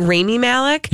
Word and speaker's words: Rami Malek: Is it Rami Malek? – Yep.